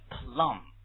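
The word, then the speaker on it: plumb: 为什么 plum 这个念 plum 不要念 plump 而且这个 B 不发音啊为什么 plum plum 管道嘛什么叫管道动词哎，在管道里探寻不就是研究吗这个词很形象啊 plum 所以它用的是比较活我们稍微介绍两个比较活的词 right?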